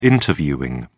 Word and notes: interviewing: British English

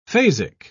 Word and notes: phasic: British English

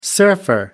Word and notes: surfer: American English

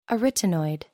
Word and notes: arytenoid: American English

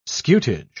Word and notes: scutage: British English

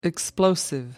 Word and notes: explosive: American English

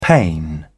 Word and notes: pane: British English

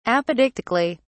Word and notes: apodictically: British English

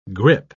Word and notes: grippe: British English

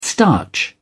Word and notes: starch: British English

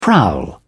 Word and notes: prowl: British English

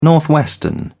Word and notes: northwestern: British English

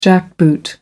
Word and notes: jackboot: American English

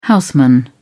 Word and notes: houseman: British English

